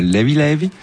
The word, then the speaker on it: Levé Levé: Alors volume 2 donc de Levé Levé, qui se prononce là-bas Levi Levi, ça veut dire léger tranquillou un peu, c'est en gros c'est il n'y a pas de stress.